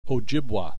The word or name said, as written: Ojibwa